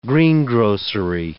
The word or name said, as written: greengrocery